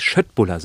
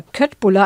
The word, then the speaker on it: köttbullar: Die Schöttbullar, so werden sie, glaube ich, richtig ausgesprochen. – Ja, das stimmt. Das habe ich auch mal gelesen, dass alle denken immer Köttbullar. Also ich dachte, es ist ungefähr 25 Jahre lang. Es heißt aber in Wirklichkeit ja Schöttbullar.